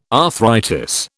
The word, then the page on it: arthritis: British English